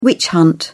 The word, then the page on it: witch hunt: British English